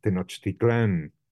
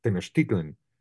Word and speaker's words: Tenochtitlan: That is, we in Spanish, it's Tenochtitlan, which is hard to say, because it has the stress at the end, has something unsatisfactory about it. But if you pronounce it in Nahua, it's just incredibly easy to say and beautiful, no? Tenochtitlan. You just move the stress and that terrifying X that is all over, you know, the Nahua.